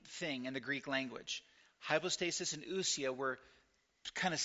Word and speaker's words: hypostasis: Hypostasis is pronounced hypostasis. And the plural is hypostases.